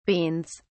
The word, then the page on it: beans: British English